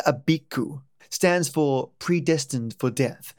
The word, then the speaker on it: Abiku: Our second spirit today is Abiku, pronounced Ab Ik U alternate titles, no other names, but often mixed up with other spirits, namely Ogbanje, and we'll cover that one later.